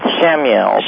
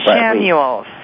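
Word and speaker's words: Chamuel: Is that kind of like Camille? Or, it's pronounced Shamuel. – Shamuel.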